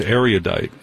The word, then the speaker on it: erudite: It's erudite. – That's what I thought. It's three syllables, right? – You can do three, but more like two. Two. Erudite. Yeah, three. That's good. – Erudite. Erudite. – Yeah, perfect. Yes.